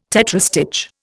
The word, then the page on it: tetrastich: Indian